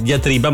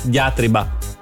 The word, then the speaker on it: diatriba: Per esempio, poco fa mi ha detto che non si dice diatriba, ma diatriba.